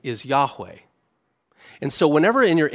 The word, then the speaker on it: Yahweh: Now, in the oldest Hebrew manuscripts, there's consonants and no vowels. But the ancient rabbis and modern scholars tend to agree that it was most likely pronounced Yahweh.